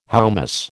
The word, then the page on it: hoummos: American English